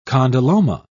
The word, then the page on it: condyloma: British English